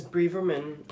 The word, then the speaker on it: Braverman: Suella Braveman. Is it Braveman? Suella Braveman, your name? – It sounds to me like that is how her surname is pronounced, is Braverman.